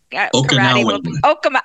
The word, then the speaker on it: Okinawa: And then our karate, traditional Okinawa, is that how you say it? – Okinawa.